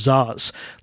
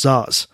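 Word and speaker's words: Szasz: Zaz. Last name is spelled S-Z-A-S-Z. S-Z-A-S-E. Thomas Zaz. I think he was from Hungary or something. That's why he has a name that, to Americanize is spelled funny.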